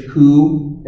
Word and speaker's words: coup: Incidentally, we get our English word through Latin and French, coup, as in coup d'etat with a silent P at the end.